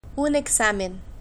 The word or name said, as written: un examen